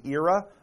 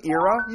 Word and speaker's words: era: era of space travel. This little word, era, you can also pronounce it era.